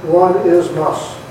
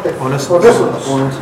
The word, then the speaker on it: Onesimus: One is must. – Onesimus.